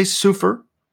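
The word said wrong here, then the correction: Seufer: It's Soifer, actually, but I appreciate you endeavoring to get it right.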